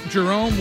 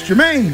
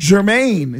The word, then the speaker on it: Jermaine: I've been calling him Jerome Wiggins for the last couple of months, and apparently it's Jeremy. – Jeremy Wiggins. – Yeah, my mother's like, I don't understand why these people can't get your name right. It's Jermaine.